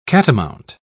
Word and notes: catamount: British English

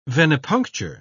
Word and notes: venepuncture: British English

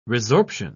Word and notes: resorption: British English

